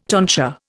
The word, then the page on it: tonsure: Indian